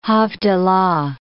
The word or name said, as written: havdalah